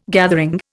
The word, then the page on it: gathering: Indian